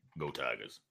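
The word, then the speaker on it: Geaux Tigers: And if you ever see Go Tigers written like that, like to where it's not like a Clemson fan, but it's an LSU guy that writes it G-E-A-U-X, you got to say it like like Coach O. – Go Tigers.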